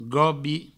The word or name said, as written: gobi